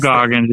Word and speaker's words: Goggins: Walt Goggins, yep.